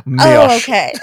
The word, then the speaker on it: Mioche: I believe it's called Mesh. Mesh. Mesh. That's the French pronunciation. – Mioche.